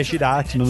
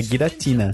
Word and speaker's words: Giratina: Não é, porque eu tava conversando com outro carinha que joga, e ele ficou falando, me corrigindo, falou assim, não, é Giratina, não sei o que. Ele falou assim, cara, pega. Aí eu fui lá e comecei a ver. Tem pronúncia. É Giratina.